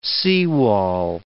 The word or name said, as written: seawall